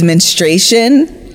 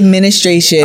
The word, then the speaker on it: menstruation: talking about the word is menstruation. And I say ministration.